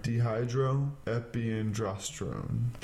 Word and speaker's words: Dehydroepiandrosterone: Dehydroepiandrosterone. Yep, there you go. Dehydroepiandrosterone.